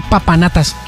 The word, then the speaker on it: papanatas: Es un patanatas, es un papanatas. ¿Qué? ¿Cómo se dice? ¿Patanatas o papanatas? – Papanatas.